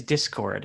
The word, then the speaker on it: Discord: And somebody tried to correct me, but I said it right. It is Discord. It's D-I-S-C-O-R-D, Discord.